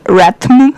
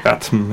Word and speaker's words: RATM: Es ist ein Mashup aus den Beatles, Joan Jett, Cypress Hill, House of Pain und ich glaube, man sagt R.A.T.M. Habe ich das richtig gesagt? Oder Ratm. – Ratm, ja. – Ratm. – Ich glaube, Ratm ist ziemlich der richtige Ausdruck.